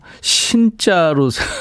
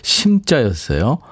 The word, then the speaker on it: 심자: 신자로 생각하신 분들이 많았네요. 6701님도 신인류의 사랑. 2014님, 주현미, 신사동 그 사람. 아유, 미안합니다. 제 발음이 좀 엉망이었죠? 심자였어요.